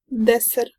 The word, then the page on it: deser: [ˈdɛsɛr]